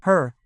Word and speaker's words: her: Her, as if there were no vowel between the H and the R, her, for it is the same as it.